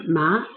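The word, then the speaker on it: ma (麻): Okay, and then move on to the second tone. It's a rising tone, so you start with middle level at 3, and then you rise it to 5. Ma, ma, again, ma. One of the words in pronounces ma in Chinese is one of the seasoning when you eat it, your tongue feels numb. So the feeling of your tongue after eating the seasoning is ma.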